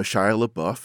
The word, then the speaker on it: Shia LaBeouf: Well, it's really interesting because, as we know, Shia LaBeouf, and a very nice job pronouncing that name. It's kind of an internet meme that people don't know how to pronounce it.